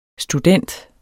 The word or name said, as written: STUDENTEN